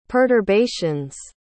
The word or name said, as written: perturbations